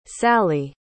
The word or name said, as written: sallie